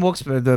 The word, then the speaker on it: Wilkes-Barre: People from Wilkes-Barre, my friends there say is pronounced Wilkes-Barre. And that sounds wrong.